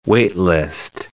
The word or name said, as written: wait-list